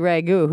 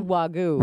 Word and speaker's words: Wagyu: How do you pronounce it? Wagyu? – I think it's Wagyu.